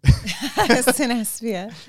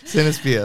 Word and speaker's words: Cinespia: How do you say it? – Cinespia. – Cinespia. That's what I thought.